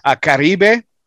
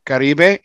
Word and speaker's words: Caribe: So it's Caribe. If you want to pronounce it somewhat as correctly as possible, Caribe, and probably rolled my R's too much because it's not the double R, but it still sounds really good coming off.